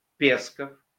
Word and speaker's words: Песков: Сын генерал-майора ПГУ КГБ СССР, пресс-секретарь Путина Песков, который предпочитает, чтобы при произношении его фамилии ударение ставили на первый слог, обычно заявляет: Кремль никогда не анонсирует возможные кадровые изменения.